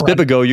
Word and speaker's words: Bibigo: Last week, it was the Lakers and Bibigo. I hope I'm pronouncing that right. It's a Korean dumpling company. – It's Bibigo. You got it right.